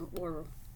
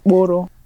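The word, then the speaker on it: mkpụrụ: Mporo is spelled M-K-P-U-R-U. So when we talk more about the vowels, we'll probably explain how you pronounce that correctly, but you probably heard the R, so Mboro. – Mboro.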